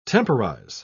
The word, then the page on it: temporise: British English